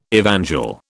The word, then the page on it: evangel: British English